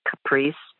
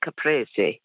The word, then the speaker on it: caprese: Yes, I was listening to the Zorba pastor on your health show today, and he said it's caprese salad. It's not. It's caprese. Caprese is a Italian term for a tomato and mozzarella dressing. So just wanted to correct his pronunciation.